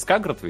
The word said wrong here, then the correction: Скарсгард: ведь? Или Сказгард? Боже мой, я загуглю, ну, пожалуй. Пускай Сказгард. – Скарсгард, да.